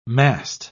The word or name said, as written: mast